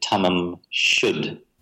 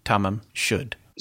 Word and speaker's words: Tamam Shud: I've spoken to students from Iran to find out how to correctly pronounce this because it's a Persian word. They've told me you should pronounce it Tamam Shud.